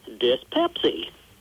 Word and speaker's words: Dispepsi: The title of Negative Land's new CD is spelled D-I-S-P-E-P-S-I. It's one word, and it's pronounced dyspepsy.